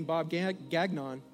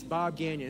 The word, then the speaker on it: Gagnon: Don't say his name Bob Gagnon because he's French and he told me. It's Bob Gagnon. It's like a soft inye or something.